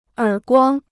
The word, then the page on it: 耳光: ěr guāng